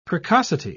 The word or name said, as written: precocity